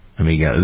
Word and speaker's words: amygdala: The bigger the brain's, how do you pronounce that? Amygdala? Amygdala.